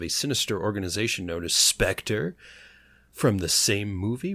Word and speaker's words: Spectre: Number 12 on our list, we have Spectre. That's how we pronounce that, right? – Yes. – Spectre.